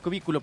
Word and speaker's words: cubículo: Por eso, cubículo. – No, cubí. Lleva acento en la I. – Ah, cubículo, perdóname.